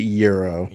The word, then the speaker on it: gyro: G-Y-R-O. – That's not a Hero. Oh, that's a Euro. – If you say Giro, get out of here. It's a Euro. – Euro. Isn't that a type Euro.